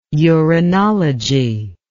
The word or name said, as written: uranology